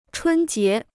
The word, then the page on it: 春节: chūn jié